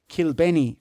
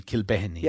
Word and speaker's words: Kilbehenny: Well, people call it Kilbenny. – Yes, the original pronunciation would be Kilbehenny. – Yeah, everyone calls it Kilbenny, and its actual pronunciation is Kilbehenny.